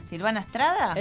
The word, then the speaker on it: Silvana Estrada: Silvana Estrada. – Exacto. – Lo dije bien. – Lo dijiste bien.